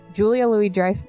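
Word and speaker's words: Julia Louis-Dreyfus: The film stars the voices of Dave Foley, Kevin Spacey, Julia Lewis, Julia Louis Dreyfus. I can never say her name right.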